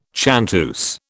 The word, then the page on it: chanteuse: British English